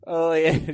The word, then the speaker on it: Ole: Did I say it wrong? – Yeah, it's Ole Anderson. – Ole, okay.